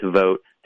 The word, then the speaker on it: Vought: It looks like it's spelled Vought, but it's pronounced Vogt, to be director of the Office of Management and Budget.